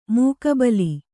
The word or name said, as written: ಮುಖಾಬಿಲೆ